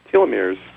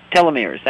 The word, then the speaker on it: telomeres: Is it telomeres? – Telomeres. – Telomeres, that's it.